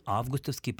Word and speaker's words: августовский: Конечно, мы говорим «август», и «августовский» производным прилагательным ударение сохраняется на том же первом слоге, что и у существительного.